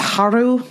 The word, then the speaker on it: Haru: And they have the moment where Legoshi makes his dramatic entrance into the lion's den to save Hal. It is Hal, right? – I think it's pronounced Haru, but yeah, I got it.